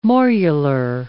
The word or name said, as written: morular